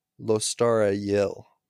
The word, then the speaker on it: Lostara Yil: the narrator of the audiobook, who is obviously the ultimate authority on all things pronunciation, pronounces her name Lostara Yil. – Yeah, I forgot the T.